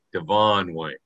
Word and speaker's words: Devon White: Devon White. – Is it Devon White? – That's the way he pronounces it, yeah.